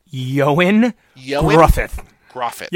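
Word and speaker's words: Ioan Gruffudd: But for all things, To be clear, his name is pronounced Yoen Gruffydd.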